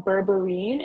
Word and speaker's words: berberine: It's called berberine. It's spelled B-E-R-B-E-R-I-N-E.